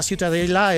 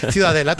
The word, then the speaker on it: Ciutadella: Para mí la Ciutadella es... ¿cómo se pronuncia? – La catalán? Ciutadella. – Ciutadella. Bueno, pues Ciudadela también en castellano.